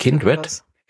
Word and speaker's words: Kindred: Kindred.